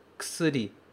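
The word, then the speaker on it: ksri (薬): A gente escreve kusuri, Mas esse k não é pronunciado como k. A gente tira esse u e deixa apenas ks, ksri, ksri.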